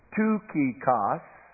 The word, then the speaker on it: Tychicus: If you were to pronounce his name phonetically in the Greek, it's Tychicus.